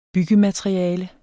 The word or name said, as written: byggemateriale